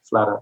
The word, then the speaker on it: Slatter: Then we've got Angela Slater, S-L-A-T-T-E-R, Slater, but pronounced Slater.